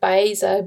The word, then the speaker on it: Baeza: And my name is Rocio, R-O-C-I-O. And the last name is spelled Baeza, B-A-E-Z-A.